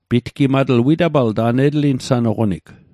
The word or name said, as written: pitgimatl